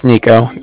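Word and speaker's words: Niko: Can you let him know that, again, my name is Nico. That's N-I-K-O with the Bank of Cardiff, and Cardiff is spelled C-A-R-D-I-F-F, as in Frank.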